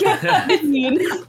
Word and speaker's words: Geraldine: Is it a soft G? – I yell from across the battlefield. It's Geraldine. – It's Geraldine. Geralt.